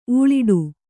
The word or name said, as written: ಉಲ್ಟಾ